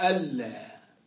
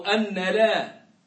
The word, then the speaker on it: أن لا: فتنطق أشهد ألا، هي وإن كتبت أن لا لكنها تنطق ألا ولا تنطق لا أو لا تنطق أن لا أو نحو هذا مما ينطق به العوام، إنما